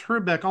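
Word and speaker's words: Hrbek: The pronunciation of Kent Herbeck, because he actually pronounced Herbeck almost like Trebek.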